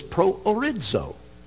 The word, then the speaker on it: proorizo: So I'll pronounce this. The verb is proorizo, orizo, horizo, horizon. Hear the word. Our word horizon comes from this word.